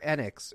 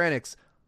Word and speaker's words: Enix: What is it with Square Enix, or is it Square Enix? Square Enix.